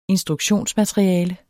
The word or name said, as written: instruktionsmateriale